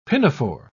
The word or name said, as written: pinafore